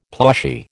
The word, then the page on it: plushy: American English